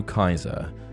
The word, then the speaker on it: Caesar: If you write Nero Caesar phonetically in Latin, you get Nero Kaiser, as the C was a hard C.